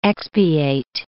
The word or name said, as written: expiate